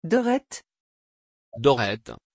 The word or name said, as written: Dorette